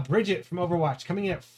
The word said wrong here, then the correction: Brigitte: It's actually a Brigitte. She's going in the Gaelic pronunciation.